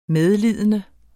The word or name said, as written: medlidende